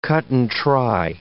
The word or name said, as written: cut-and-try